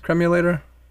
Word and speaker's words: cremulator: I had this picture in my mind of, is it pronounced cremulator? – Cremulator, yeah.